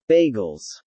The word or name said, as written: Bagels